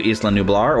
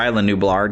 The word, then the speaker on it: Isla Nublar: She's the granddaughter of James Cromwell's Benjamin Lockwood, who is a millionaire who is basically funding all of this expedition back to Isla Nublar, or Isla Nublar, depending upon how you would pronounce that. We usually call it Isla Nublar for fans of the original movies, but I've been hearing, you know, Isla Nublar because, you know, people who are named Isla, they spell it the same way, but they'll pronounce it Isla, and if you think island, so I get it, you know.